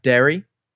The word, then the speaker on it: Darry: Yeah, apparently Darry. But his name's Darry, but I think they say it like Derry.